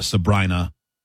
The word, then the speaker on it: Sabrina: I think it's Sabrina, though, because I remember reading somebody said that the pop singer rhymes with vagina. Sabrina.